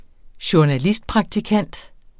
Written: journalistpraktikant

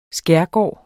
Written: skærgård

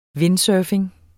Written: windsurfing

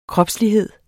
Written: kropslighed

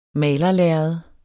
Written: malerlærred

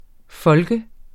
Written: folk

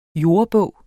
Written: jordebog

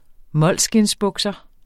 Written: molskind

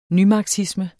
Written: nymarxisme

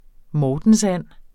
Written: mortensand

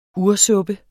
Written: ursuppe

